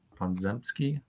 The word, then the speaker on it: Podziemski: I just his name Pon- Ponjemski. Pon- Podziemski.